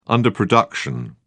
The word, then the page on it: underproduction: British English